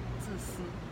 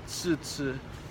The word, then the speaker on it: 自私: 自私。 – 自私。 – 自私。